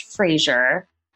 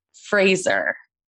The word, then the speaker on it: Fraser: His name is not pronounced Fraser. It's Fraser. Correct? – Wow. Very spot on. One of the first Americans that said it correctly.